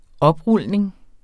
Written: oprulning

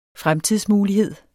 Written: fremtidsmulighed

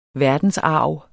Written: verdensarv

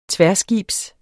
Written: tværskibs

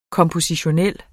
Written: kompositionel

